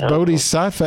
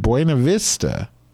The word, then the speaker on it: Bodhisattva: It's Bodhisattva, and he said Buena Vista. I'm like, okay, so he does Reiki, and he has Tibetan singing bowls, and he doesn't even know how to pronounce the name of the Buddha and pronounce it like it's the Buena Vista Social Club.